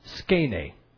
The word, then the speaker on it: skene: the word for tabernacle or temporary tent is skene. How would we spell that? S-K-E-N-E, I guess.